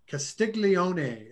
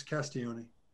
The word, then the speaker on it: Castiglione: Again, Paul Castiglione, first time I met him, Castiglione is how I pronounced it. And he goes, no, it's Castiglione.